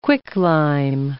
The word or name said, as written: quicklime